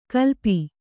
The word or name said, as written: ಕಲ್ಪಿ